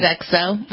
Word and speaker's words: Sodexo: The allergy alert on, and I asked you before how to pronounce this, Sodexo, is that it? – Sodexo, mm-hmm.